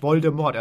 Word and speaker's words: Voldemort: Genauso wie sich die Leute immer beschweren über Voldemort. Und dann immer reinhauen. – Ja. Es heißt Voldemort, Voldemort.